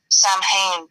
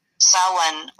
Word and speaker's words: Samhain: Which is spelled, and what probably people from like America would pronounce it as it's spelled in English, Samhain, but in Irish it is Samhain, or yeah. Depending on the part of the country, Samhain or Samhain.